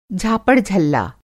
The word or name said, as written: झापड़-झल्ला